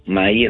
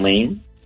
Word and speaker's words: Maie: M-A-I-E, Maye, no Main.